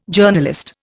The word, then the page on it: Journalist: Indian